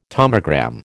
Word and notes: tomogram: American English

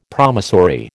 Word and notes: promissory: American English

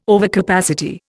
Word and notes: overcapacity: Indian

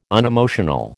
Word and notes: unemotional: American English

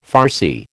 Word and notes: farcy: American English

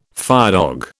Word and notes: firedog: British English

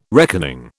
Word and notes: reckoning: British English

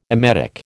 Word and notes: emetic: American English

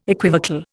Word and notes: equivocal: Indian

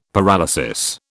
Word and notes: paralysis: British English

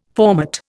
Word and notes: formate: Indian

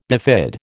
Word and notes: bifid: American English

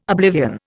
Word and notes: oblivion: Indian

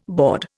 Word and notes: board: Indian

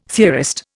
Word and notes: theorist: Indian

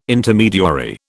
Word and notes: Intermediary: British English

